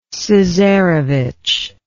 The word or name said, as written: cesarevitch